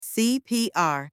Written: CPR

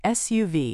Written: SUV

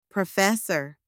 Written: professor

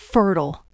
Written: fertile

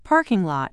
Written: parking lot

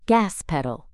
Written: gas pedal

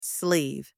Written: sleeve

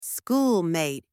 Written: schoolmate

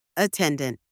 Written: attendant

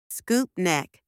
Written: scoop neck

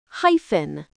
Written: hyphen

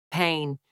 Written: pain

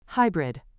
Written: hybrid

